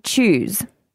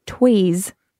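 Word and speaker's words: Tewes: This person said, fact check from yesterday, Lauren Chews is actually pronounced Tweez.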